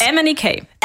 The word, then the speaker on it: MNEK: MNEK.